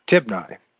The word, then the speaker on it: Tibni: I've been saying it wrong. It's Tibni, the son of Gineth.